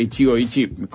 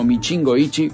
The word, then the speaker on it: Ichigo Ichie: Me gusta porque cuando dije Ichigo Ichi con mi pronunciación en japonés, uno lo entendió como Michingo Ichi, que es como que lo mexicanizó.